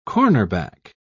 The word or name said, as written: cornerback